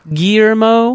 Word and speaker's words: Guillermo: Now, I didn't practice this name, and I know you're not supposed to pronounce the L's. It's Guillermo.